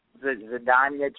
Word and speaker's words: Zdeněk: Zdeněk Chara, yeah, that's his name.